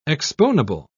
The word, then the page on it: exponible: British English